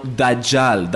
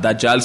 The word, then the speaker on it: Dajjal: Que ellos tienen un personaje llamado Dajjal. Dajjal se escribe D-A-J-J-A-L.